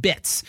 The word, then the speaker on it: BTZ: Now, Buns is offering a genuine alternative, a form of cryptocurrency called BTZ, pronounced bits.